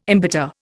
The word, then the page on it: embitter: Indian